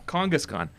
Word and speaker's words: Kangaskhan: Is that, am I saying it right now? You want me to elongate the A's? – Yeah, you got it. Kangaskhan. – Kangaskhan.